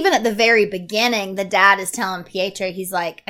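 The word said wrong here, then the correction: Pietari: Petari. – Oh my God. – Petari.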